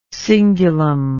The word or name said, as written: cingular